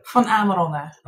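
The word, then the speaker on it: Van Amerongen: How do you say your last name? – Van Amarongen. – Okay, it's Dutch.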